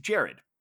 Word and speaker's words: Gerard: And when I asked the guy behind the bar, when I asked Jared, it's confusing because his name is spelled G-E-R-A-R-D, like Monsieur Depardieu and Mr. Butler. – Yes, yes. – But it's pronounced Jared.